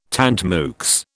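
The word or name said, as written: tant mieux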